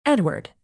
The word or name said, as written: edward